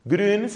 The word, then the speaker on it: Grün: Entonces tenemos a un monje benedictino que se llama Anselm Grün, G-R-U-N, la U se escribe con diéresis, Grün se pronuncia, esa U suena como la U francesa, Anselm Grün, es un autor, es un bestseller, es un autor popularísimo.